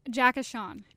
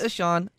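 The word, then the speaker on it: Ahcan: How do you officially pronounce the name for Tory Krug 2.0? – Jack Ashawn. – Is it Ashawn? Yes.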